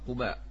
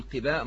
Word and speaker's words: قباء: باب مسجد قباء، وهي قول واحد قباء، يعني هي تنطق قباء، أما البعض يقول قباء ما غير صحيح.